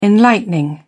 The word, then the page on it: enlightening: British English